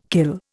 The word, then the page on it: kill: Indian